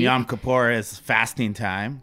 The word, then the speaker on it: Yom Kippur: So happy Yom Kippur. Am I pronouncing that correctly? – You are.